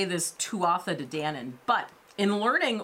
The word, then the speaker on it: Tuatha: Uh, that's at least the way that I've always heard it pronounced the Tuatha Yeah.